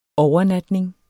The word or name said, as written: overnatning